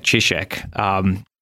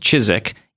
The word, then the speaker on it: Ciszek: That's a good Polish way of pronouncing it, Ciszek. In America, oftentimes it's pronounced Ciszek. Either way is acceptable, but you are a true client of his, if you pronounce it the proper way, which you have.